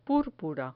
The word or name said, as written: purpura